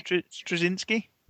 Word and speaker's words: Straczynski: And, you know what I mean, Straczynski. – Yeah, Babylon 5 guy. – I can't pronounce words, but yeah. – Straczynski? – There you go.